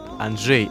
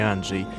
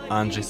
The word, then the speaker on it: Анджей: У ведущего случилось техническое замыкание, поэтому половину выпуска он неправильно ставит ударение и говорит «Анджей», а не «Анджей». Конечно же, мы знаем, что автора зовут Анджей Сапковский.